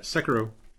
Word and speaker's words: Sekiro: I went back to, I always say this wrong, Sekiro. – Yeah, you got it, Sekiro.